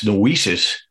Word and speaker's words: noesis: Uh, am I pronouncing it properly? – Well, uh, because of the way you're pronouncing it, that's why we put the uh, that's why we put the bar over the e because it's very easy to mispronounce. Um, it's noesis, um, and that's a Greek word that means um, the arrival place of intelligence and understanding.